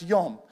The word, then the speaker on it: yom: They draw from the Hebrew word for day, which is pronounced yom, where we get the term Yom Kippur, which is the Day of Atonement, right?